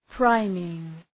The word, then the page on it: priming: ‘praımıŋ